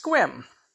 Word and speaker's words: Sequim: That's why something that looks like sequim is pronounced squim.